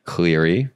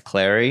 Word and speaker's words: Clary: What'd you say? Cleary. That's Clary. It's Clary.